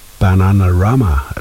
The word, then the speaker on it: Bananarama: En sånn en damegruppe som heter Bananarama. Bananarama, det sånn det uttales.